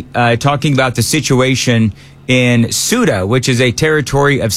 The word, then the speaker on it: Ceuta: All right, so we'll call it Ceuta from now on. S-O-O-T-A, Ceuta.